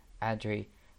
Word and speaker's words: Adri: it's technically my name is Adrienne. Um, I just go by Adri, but everybody here pronounce it pronounced it Adri. It, it's fine.